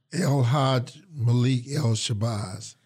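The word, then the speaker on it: El-Hajj Malik El-Shabazz: How do you say the full name? It's El Haja? – El Haj Malik El Shabazz.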